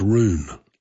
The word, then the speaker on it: Run: But anyway, the Brits held out, and they had one little island that they fought to keep, and it was spelled R-U-N, pronounced Rune.